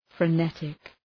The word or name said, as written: frenetic